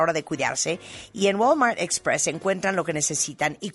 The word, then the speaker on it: Walmart Express: Oigan, son las 11:53 de la mañana, ya saben que somos fans en este programa de Walmart Express. ¿Lo dije bien? – Muy bien, Walmart Express.